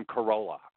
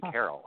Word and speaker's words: Carolla: I couldn't I couldn't pronounce Adam Carolla. I couldn't pronounce Adam Carolla's name. I I, I was calling him Adam Carroll.